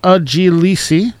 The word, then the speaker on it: Ujilisi: And appropriately, I'm going to take you out with some Sax Mile, their track, which in Cherokee means my grandma, and it's pronounced Ujilisi.